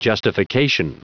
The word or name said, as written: justification